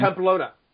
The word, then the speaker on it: Pamplona: You know, another question I had for you on the Camino, you go through and correct me if I pronounce this wrong. Mm-hmm. Pompano, is it Pompano, Spain? – Pamplona.